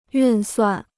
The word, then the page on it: 运算: yùn suàn